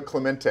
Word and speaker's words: Clemente: Adam Wainwright, for sure, with the Clemente, I know I'm probably saying it wrong, award that he received, and you can say it very nicely. – Roberto Clemente. – Yeah. There we go, with that Clemente, award.